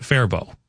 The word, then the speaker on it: Faribault: Another town name that's often mispronounced is Faribault, Minnesota. Pronounced Faribault.